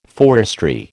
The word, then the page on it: forestry: American English